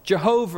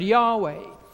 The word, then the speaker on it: Yahweh: Four letters in our English, YHWH, from whence the theologians, the scholars think that the best pronunciation is not Jehovah, as we used to say, but Yahweh.